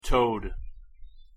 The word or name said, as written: toad